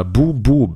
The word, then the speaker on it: Boom Boom: äh, weil wir wollten natürlich auch noch mal ganz kurz über Boris Becker sprechen, die Boris Becker Dokumentation Boom Boom oder Boom Boom im Englischen, The World vs. Boris Becker. Und man muss es Englisch aussprechen, weil es ist ja eine englische Dokumentation.